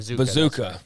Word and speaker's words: Mazcua: Mazuka, Like Mazooka. Yeah. It's spelled Mazcua, though. But yeah, Mazooka.